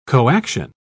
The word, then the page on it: coaction: British English